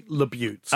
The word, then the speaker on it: LaBute: I would say LaBute. – Okay, great. Well, not LaBute.